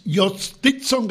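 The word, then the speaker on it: Jhostynxon: Yostizon García.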